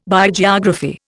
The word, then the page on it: Biogeography: Indian